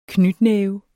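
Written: knytnæve

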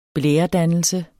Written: blæredannelse

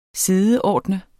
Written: SIDEORDNEDES

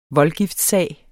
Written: voldgiftssag

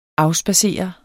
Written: AFSPADSEREDE

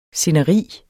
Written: sceneri

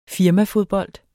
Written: firmafodbold

